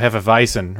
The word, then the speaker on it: Hefeweizen: Remember W's in German are pronounced as V's, so Hefeweizen, right? – Ah, okay.